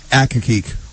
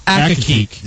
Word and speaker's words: Accokeek: How you say it? – Accokeek. – Accokeek.